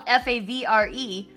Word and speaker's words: Favre: And spell uh, you say it's spelled F A V R E, but you say uh, he's he pronounces it Favre, which is putting the R in front of the fucking V.